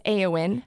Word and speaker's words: Eowyn: So it's Eowyn. You pronounce the E like an A, which is what throws everybody.